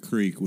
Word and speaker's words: creek: And that day at the creek was just such an awesome day. – Is it pronounced day. – Creek? It's not Crick? – It's not Crick.